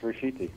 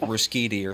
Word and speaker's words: Ruscitti: A lot of people would have Americanized it to like Ruscitti or something.